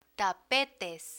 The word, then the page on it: tapetes: Spanish